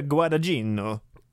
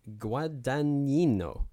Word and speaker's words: Guadagnino: I asked Luca Guadagnino. – Yeah, I asked my brother, who speaks Italian, how to pronounce this guy's name. He said something like Guadagnino. – Guadagnino.